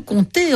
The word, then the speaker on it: compter: compter, on ne manque pas d'occasion de se tromper à l'écrit. En tout cas, à l'oral, on ne prononce pas le paix.